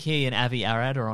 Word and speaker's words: Avi Arad: I know nothing about Avi Arad. Honestly, I don't even know how to pronounce his name correctly. – No, you're nailing it.